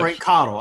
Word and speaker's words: Cottle: Frank Cottle.